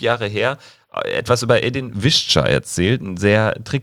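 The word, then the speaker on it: Edin Višća: Zu Edin Visca ganz kurz.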